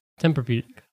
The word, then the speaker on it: Tempur-Pedic: Therapeutic. – Tempur-Pedic. – Yeah. – Therapy? – No. Temper. – Tempur-Pedic.